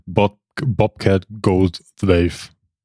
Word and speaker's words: Bobcat Goldthwait: Er verbündet sich ja dann mit Bobcat Goldthwait. Wie spricht man es aus? – Bobcat Goldthwait.